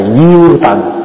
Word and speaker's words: Giao: Thì bây giờ Pháp Hòa thưa đại chúng, khi mà chúng ta đọc trong kinh, chúng ta để chữ Giao Tần. Có khi không đọc là Giao mà đọc là Diêu Tần. Diêu hay Giao cùng một chữ.